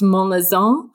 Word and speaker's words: Monlezun: My name is Anna Clare Monlezun in American, and it's Basque French. So it would be pronounced Monlezun, and it means the glow or light behind the mountain.